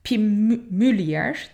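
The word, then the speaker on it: Pim Mulier: Ta ki 1890'da Frizyalı bir Pimülier diye okunuyor.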